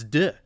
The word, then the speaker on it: Deux: And Folie à Deux is what we've been saying, and it was musical, but it's Deux.